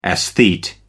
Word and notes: esthete: American English

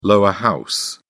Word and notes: lower house: British English

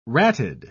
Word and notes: ratted: British English

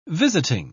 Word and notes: visiting: British English

